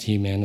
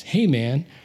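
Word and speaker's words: Heman: But actually, it's not pronounced He-Man. It's pronounced Hey-Man.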